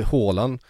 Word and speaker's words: Haaland: Då får du Haaland. Och då kommer det vara typ rätt. – Och sen är väl på norska är väl det nästan tyst, va? – Det blir Haaland.